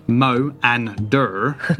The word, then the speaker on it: Moanda: So Moanda, pronounced Mo-an-dur, was an ancient deity of had venerated Moanda since before the rise of some ancient magical people called Nithril.